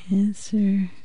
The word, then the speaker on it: answer: Or answer... P. – W. – W and Neil.